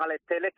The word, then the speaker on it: Alex Telles: Pero en la presentación, bueno, en el vídeo que ha hecho promocional tras firmar el contrato, él ha dicho Alex Telles, así que pues pronunciaremos Alex Telles,